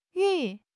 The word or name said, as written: 玉